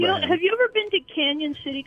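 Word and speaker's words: Cañon City: It's spelled Canon, but it has like, so you think it's Canon, but it has the little tilde over that one N.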